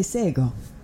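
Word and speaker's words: Shé:kon: When I first opened the show, you heard me say Sego. That is a Gonyageha word for hello, but it's not spelled like it sounds. It's spelled SHE:KON. The letter K sounds like a G.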